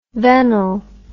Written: vennel

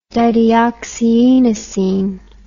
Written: dideoxyinosine

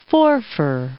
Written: Forfar

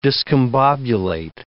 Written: discombobulate

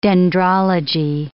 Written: dendrology

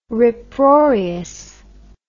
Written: riproarious

